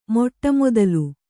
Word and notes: ಮೊಟ್ಟಮೊದಲು: Kannada

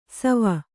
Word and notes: ಶವ: śava

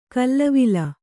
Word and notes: ಖಲೇವಾಲಿ: khalēvāli

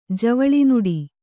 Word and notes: ಜವಳಿನುಡಿ: Kannada

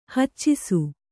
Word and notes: ಹಚ್ಚಿಸು: haccisu

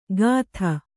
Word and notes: ಗಾಥ: gātha

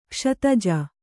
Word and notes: ಕ್ಷತಜ: kṣataha

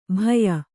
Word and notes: ಭಯ: bhaya; kannada

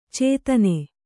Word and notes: ಛಾದನ: chādana

